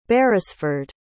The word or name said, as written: beresford